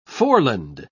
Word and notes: foreland: British English

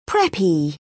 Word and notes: preppy: British English

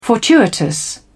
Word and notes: fortuitous: British English

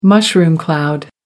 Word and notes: mushroom cloud: American English

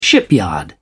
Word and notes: shipyard: British English

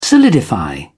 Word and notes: solidify: British English

